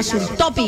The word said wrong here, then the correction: Sultofi: insulina Sultopi. – Sultofi. – Sultofi, eso, Sultofi. Usted que es prácticamente alemán, pronuncia mejor que yo.